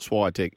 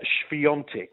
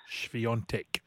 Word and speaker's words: Świątek: If you read it as written, it looks like Swiatek, and I know it's not Swiatek. Teach us how to say her surname properly. – Yeah, so it is Iga Świątek.